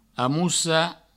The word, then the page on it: amocar: amoussa